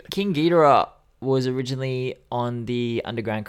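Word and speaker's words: Geedorah: Yeah, Ghidorah, Ghidorah, is that how you say it? – Ghidorah. It's said so many times in different ways on the album itself, and it pisses me off.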